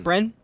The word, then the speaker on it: Brenne: So far, we have had Brenne. Am I saying that right? – Brenne.